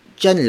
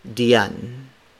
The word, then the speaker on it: diyan: jan is spelled as D I Y A N. So the D I Y combination is often pronounced in Filipino like the letter J, similar to the letter J.